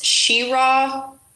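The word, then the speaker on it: Cheraw: He's from, I think it's pronounced Shero, South Carolina.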